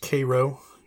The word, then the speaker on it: Cairo: So it's, I believe, pronounced Cairo. – Probably, in the local parlance.